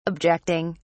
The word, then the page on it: objecting: British English